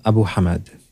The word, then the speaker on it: Abu Hamid: Abu Hamid. – Abu Hamid. – Yeah, that H. We have kind of three H-ish sounds in Arabic. We've got the normal H, like you would in English, ha.